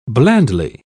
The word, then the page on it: blandly: British English